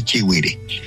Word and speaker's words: Chihuire: formas la pertenencia de gente del interior del país, la llegada a los llanos, ha cambiado y ha variado en alguna medida la pronunciación del nombre, pero el nombre correcto es Chihuire. Eso para dejar claro sobre el nombre.